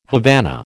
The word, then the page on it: Havana: American English